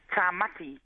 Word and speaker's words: Tamati: But I think you pronounce it here Tamati, or how would you pronounce it in Māori? Is that right? – Tamati means Thomas. – And in Samoan we pronounce it Tamati.